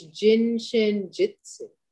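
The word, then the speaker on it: Jin Shin Jyutsu: And I'm pretty excited today because I get to have a conversation with a practitioner of Jin Shin Jiu-Jitsu, which hopefully I said that correctly. Thank you for being with me today. – Thank you, Kara. And you are almost right. It's Jin Shin Jitsu.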